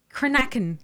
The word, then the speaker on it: cranachan: Cranachan. – Cranachan. – Cranachan. – Cranachan. – Cranachan. – Okay. – Hang on, let's spell it. C-R-A-N-A-C-H-A-N. – It's a Gaelic word.